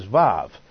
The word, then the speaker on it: Vav: And the 6th letter is Vav. Modern Hebrew speakers have lost the W sound. They pronounce it as a V. And so it's called Vav, and that's the 6th letter.